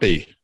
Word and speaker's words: Stoppe: Am I pronouncing your last? – Stop. Stoppy. Stoppy. Stoppy.